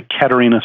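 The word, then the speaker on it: Catharina: It's actually Katharina sour. The okay. H is kind of silent.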